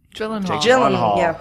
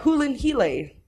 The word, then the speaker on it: Gyllenhaal: All right, starring Jake Gyllenhaal. – Hulin Hile. I think that's how you're supposed to pronounce his name.